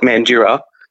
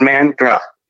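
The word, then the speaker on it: Mandurah: Because we do have a letter in this, M-A-N-D-U. No one uses the U. It's just Mandra. That's how we pronounce it. We just forget that there's a whole extra vowel right in the middle. We don't care for it, but no, we don't need it. It's Mandra.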